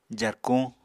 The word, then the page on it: jarkuñ: apatani